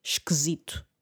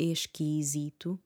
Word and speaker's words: esquisito: muito este i, parece esquisito. até um é esque, esquisito, não é? Uh, normalmente, quando dizemos a palavra rapidamente, a menos que estejamos a pronunciar cada sílaba é esquisito, não é? Mas normalmente dizemos esquisito. Esqu pelo menos eu digo assim. Ah, deixa de ser esquisito.